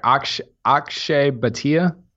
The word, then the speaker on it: Akshay Bhatia: Yeah, the left-hander, Akshay Bhatia.